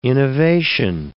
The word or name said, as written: innovation